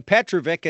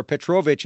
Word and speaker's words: Petrovic: Well, whether you pronounce his name Petrovic, as Josh Bogorad did, or Petrovic, as the Florida Panthers announcers used to pronounce it, he was really the lightning rod of this particular game, because he did direct the puck forward with his skate.